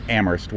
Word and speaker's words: Amherst: Which is Amherst, where only the H is silent.